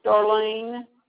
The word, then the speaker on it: Darlene: Darlene. – Just spell it, please. – D-A-R-L-E-N-E. – And your last name? – Grissett. G-R-I.